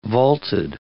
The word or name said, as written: vaulted